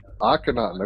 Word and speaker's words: Akhenaten: Akhenaten.